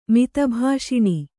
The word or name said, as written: ಮಿತಭಾಷಿಣಿ